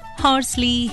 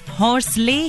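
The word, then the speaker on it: Horsley: अच्छा, वैसे आम बोलचाल में इस जगह को ना हॉर्सले हिल्स भी कह दिया जाता है, लेकिन अगर इंग्लिश में नाम की स्पेलिंग और कलेक्टर साहब के नाम पर गौर करें तो सही प्रोनंसिएशन होगा, यानी सही उच्चारण होगा हॉर्सली।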